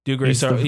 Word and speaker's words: Dougray Scott: Can we just, I just want to talk for a sec about Mr. is it Dougray Scott? Is that how you say his name? – Dougray Scott.